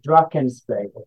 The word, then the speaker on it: Drakensberg: Um, I will help you how to pronounce the mountains where I started in South Africa. It is called the Drakensberg, the Drakensberg mountain range.